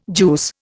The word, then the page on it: juice: Indian